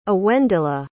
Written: awendela

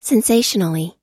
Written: sensationally

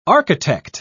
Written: architect

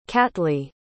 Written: catlee

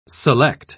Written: select

